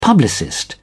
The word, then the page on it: publicist: British English